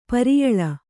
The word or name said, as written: ಪ್ರಿಯಾಲ